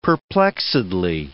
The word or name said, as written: perplexedly